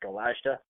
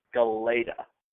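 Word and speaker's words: Galajda: And their goaltender, Matt Galeta, has, and by the way, we've been mispronouncing it on the podcast here for a little bit, accidentally calling it Matt Galashta. From what I heard over the weekend in covering this team at Bright Landry, it's Galeta. The J is silent, just as a little thing.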